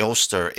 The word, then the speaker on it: Eostre: And when I say Oster, it's spelled E-O-S-T-R-E or Ostara, O-S-T-A-R-A, which this goddess is the same goddess that we've been talking about along with the other names.